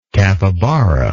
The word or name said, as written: capibara